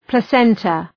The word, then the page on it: placenta: plə’sentə